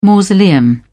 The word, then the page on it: mausoleum: British English